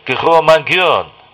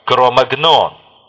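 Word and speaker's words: Cro-Magnon: Название кроманьонцы французского происхождения, от пещеры Кро-Магьон в Дордони, на юго-востоке Франции, где впервые были найдены их останки. Англоязычные североамериканцы обычно произносят его как Кро-Магнон».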